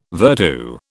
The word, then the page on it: virtu: British English